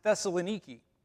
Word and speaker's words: Thessaloniki: and she has an apartment in Thessalonica, or actually these days it's pronounced Thessaloniki.